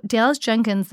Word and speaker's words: Dallas Jenkins: So Dallas Jenkins, the creator, did I say that right? – Uh-huh.